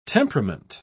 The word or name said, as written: temperament